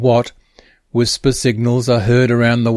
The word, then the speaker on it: WSPR: An early mode still widely used today is WSPR, pronounced as whisper,